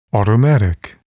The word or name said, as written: automatic